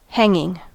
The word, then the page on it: hanging: American English